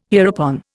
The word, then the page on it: hereupon: Indian